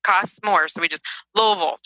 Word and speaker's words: Louisville: But uh, I do know how to say the city that you live in, Louisville.